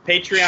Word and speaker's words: Patreon: Um, next week, it's a commentary, a Patreon, pat- patron, Patreon, patron. How do you say it? Patreon, sure.